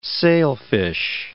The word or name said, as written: sailfish